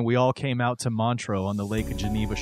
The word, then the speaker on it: Montreux: Yeah, you were saying it right. It is Montreux.